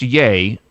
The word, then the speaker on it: YEA: Now, it's pronounced Yay, but it's spelled Yay as in Y-E-A camp, correct? – That's right.